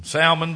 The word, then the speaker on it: Salmon: Salmon begat Booz of Rachab. That was not Salmon. We can pronounce the L in this one.